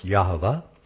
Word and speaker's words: Yahweh: But if you look at the Hebrew, at the Hebrew, or if you check the concordance, you'll find that it's spelled YHVH, which is pronounced Yahweh.